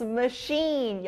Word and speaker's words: machine: Yes, machine. 여기 sh 소리가 나요.